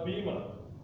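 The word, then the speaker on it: bima (βῆμα): It is a Greek word, it's pronounced bima. Bima, and so it has a sense of judgment seat.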